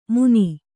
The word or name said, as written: ಮುನಿ